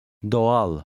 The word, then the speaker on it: Doğal: The soft G lengthens the first vowel. Read word 13. – Doğal.